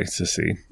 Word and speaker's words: Cissy: So it's spelled Cissy, Sissy.